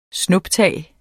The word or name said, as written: snuptag